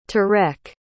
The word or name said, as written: tareq